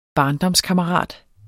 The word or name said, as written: barndomskammerat